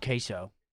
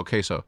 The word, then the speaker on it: Queso: Breakthrough streamer, KCO. Is that how you say it, right? KCO? – Oh, he won. – I thought it was just Queso. – Oh, Queso. – Like cheese.